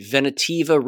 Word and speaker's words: Ventavia: Brook Jackson, who worked for Pfizer's contractor, uh, let me see, Ventavia Research Group, didn't prove violations of the False Claims Act. U.S. District Judge Michael Truncale ruled on March 31st. Jackson sued Ven- Tavia, that's how you pronounce it,